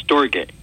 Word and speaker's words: Storge: Well, the way Lewis pronounced it was Storge.